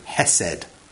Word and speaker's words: hesed: It is the word hesed. That's how we pronounce it in English anyway. It's H-E-S-E-D in its transliteration, all right?